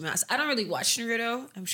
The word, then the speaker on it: Naruto: really watched Naruto.